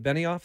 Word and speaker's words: Benioff: Weiss and David, is it Benioff? Is that how you say his name? – Sure.